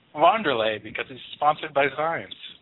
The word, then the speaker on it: Wanderlei: Wanderlei Silva, or Wanderlei, as it's supposed to be pronounced.